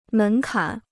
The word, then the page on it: 门槛: mén kǎn